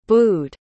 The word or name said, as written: booed